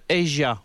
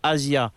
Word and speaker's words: Asia: Je veux parler de Asia, ou pour les gens qui ne prononcent pas bien l'anglais, Asia.